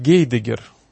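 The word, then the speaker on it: Heidegger: Но любопытный факт, о котором, возможно, не знают наши радиослушатели, то, что изначально имя Хайдеггера звучало по-русски иначе, в соответствии с правилами транслитерации, его произносили как Гейдеггер.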